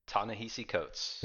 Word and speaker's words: Ta-Nehisi Coates: Ta-Nehisi Coates.